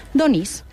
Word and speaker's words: Donís: I és que la pronúncia pròpiament valenciana tradicional és Donís. – O sigui que Donís no és simplement una manera de dir malament Dionís.